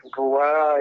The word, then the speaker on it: Ruah: Rua es, se escribe R-U-A-H, 60 en número y más en letras CO.